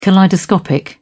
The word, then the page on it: kaleidoscopic: British English